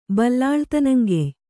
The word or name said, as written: ಬಲ್ಲಾಳ್ತನಂಗೆಯ್